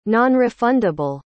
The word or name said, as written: nonrefundable